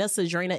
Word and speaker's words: Cedrinna: We are on YouTube underneath my platform, Sincerely Cedrinna. Follow the Instagram page, Sentimental Value Pod. If you want to follow my handles, it is Sincerely Cedrinna. Cedrinna is spelled C-E-D-R-I-N-N-A, okay?